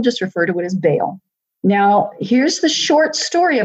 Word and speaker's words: Baal: And if you're pronouncing it correctly for the scholars, if there are any scholars out there trying to think we don't know what we're talking about, technically it's supposed to be said Baal.